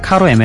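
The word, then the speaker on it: Caro: 저는 근데 이 카로라는 그러니까 C로 시작하는 발음을 카바니라는 뭐 축구 선수도 까바니가 되고 왠지 저는 쌍기역으로 발음하게 되더라고요. 습관처럼. 그래서 카로 에메랄드보다는 까로 에메랄드 이렇게 발음하게 되더군요.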